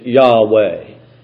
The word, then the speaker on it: Yahweh: Actually, we don't really know how to pronounce this word that is translated Lord here. The best pronunciation that we can come up with is Yahweh.